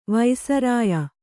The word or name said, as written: ವೈಸರಾಯಿ